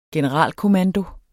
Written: generalkommando

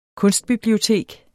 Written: kunstbibliotek

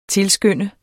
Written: tilskynde

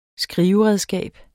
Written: skriveredskab

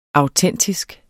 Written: autentisk

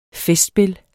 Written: festspil

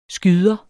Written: skyder

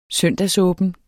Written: søndagsåben

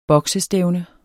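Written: BOKSESTÆVNE

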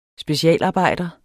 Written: specialarbejder

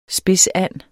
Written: spidsand

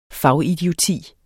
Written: fagidioti

